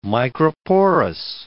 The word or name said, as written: microporous